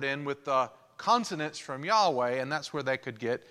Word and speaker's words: Yahweh: Another way to pronounce it is Yahweh Jireh, because we're not quite for sure how to pronounce the name Yahweh or Jehovah. In ancient Hebrew letters, they didn't have vowels. They just had consonants. So they assumed how these things were pronounced. So Yahweh is spelled Y-H-W-H, but sometimes Y has a J sound, and sometimes W has a V sound. So that's where you could get Yahweh, or you could get Jehovah. And at some point in time, they started mixing in vowel letters, and they didn't like saying God's name.